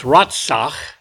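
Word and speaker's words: Ratzach (רצח): The word translated kill is Strong's 7523, pronounced Ratzach, and it refers to the unsanctioned taking of life.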